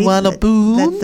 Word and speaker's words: Suvarnabhumi: Suvarnabhumi, just outside the capital city of Bangkok. Look, I had to write it down phonetically. – Suvarnabhumi. – That's how it's supposed to be.